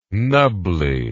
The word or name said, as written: knubbly